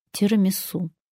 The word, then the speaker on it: тирамису: Десерт к кофе латте я прошу тирамису, не тирамису. Это сложное составное слово, в итальянском языке оно вообще пишется в три слова. Ударение в русском языке зафиксировано. Об этом можно узнать в орфоэпическом словаре. Правильно называть этот вкуснейший десерт – тирамису.